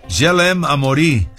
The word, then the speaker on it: Je l'aime à mourir: Je l'aime à mourir, sin la R al final. No se pronuncia.